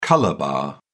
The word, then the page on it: colour bar: British English